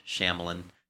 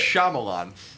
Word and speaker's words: Shyamalan: By the way, I say it is the Indian way, not the English Shyamalan. – How do you say it? – Shyamalan. Oh yeah. So I know. I'll never. – It's Shyamalan.